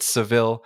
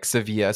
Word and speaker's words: Sevilla: For the listeners, us Anglo speakers have called it Seville, but the right way to say it, according to the Spanish, is more like Sevilla.